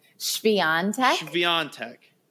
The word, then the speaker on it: Świątek: You did not, but that's okay. You were very close. – Say it for me. – Świątek. Yep. Right, now, there's no W, but it's, yeah, and there's no SH either, but that is Polish for you.